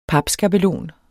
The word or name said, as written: papskabelon